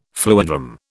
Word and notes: fluidram: British English

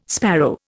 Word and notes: sparrow: Indian

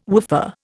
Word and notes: woofer: Indian

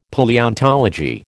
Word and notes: Palaeontology: American English

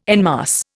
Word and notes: en masse: Indian

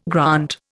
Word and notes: grant: Indian

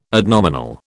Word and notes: adnominal: British English

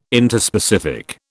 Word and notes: Interspecific: British English

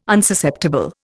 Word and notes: Unsusceptible: Indian

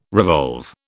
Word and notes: revolve: British English

